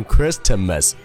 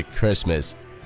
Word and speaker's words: Christmas: 第二点，这个 Christmas 中的字母 T 它是不发音的，所以说不会读成 Christmas，而是 Christmas。